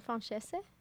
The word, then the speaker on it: francese: Hey, Balti, how do you say that one, like, chicken dish that's, like, lemon sauce on it? Chicken francese? – Oh, I thought you wanted it. – I don't francese. – Actually know. – That's how you say it. – Francese.